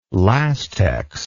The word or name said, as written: Lastex